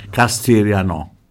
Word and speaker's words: Castigliano: Castigliano, con l'accento sulla O.